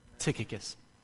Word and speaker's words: Tychicus: Tychicus will tell you all about, and that is how you pronounce it, I did the homework on that. Tychicus. Tychicus sounds a little better.